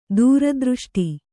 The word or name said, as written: ದುರದೃಷ್ಟ